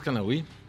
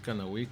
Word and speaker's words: Canahui: Sí, estoy como Alexis Canahui. Alexis Canahui. – ¿Cómo se escribe tu apellido? – C-A-N-A-H-U-I.